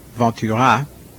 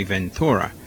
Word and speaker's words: Ventura: I would like to start with a couple of recordings by Ray Ventura. That's the way it's pronounced in French. Here, I guess we would say Ventura.